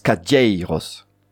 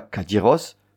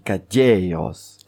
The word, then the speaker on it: cadieiros: Par exemple, premier exemple, las cadieiros. Vous avez entendu cadieiros. Cadieiros. Tu l'entends, Corto? On dit pas cadiros, on dit cadieros.